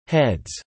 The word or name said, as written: heads